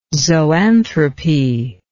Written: zoanthropy

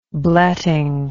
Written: bletting

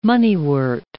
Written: moneywort

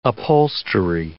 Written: upholstery

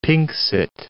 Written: pinxit

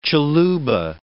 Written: Tshiluba

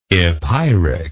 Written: epeiric

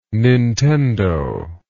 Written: nintendo

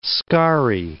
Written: scarry

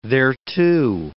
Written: thereto

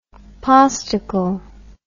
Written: postical

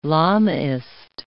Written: Lamaist

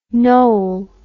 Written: Noll

